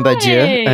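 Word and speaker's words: Badiou: By Alain Baudieu. I'm probably saying that wrong. – No, that's right.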